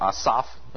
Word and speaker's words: Asaph: Book 3 picks up with some psalms of Asaph, or Asaph, as it probably should be pronounced in Hebrew.